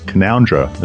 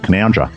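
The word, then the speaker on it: Canowindra: but he was here in a neighboring town called Canoundra. It's spelled Canawindra. But if you come here, don't ever say that. It's because everyone calls it Canoundra.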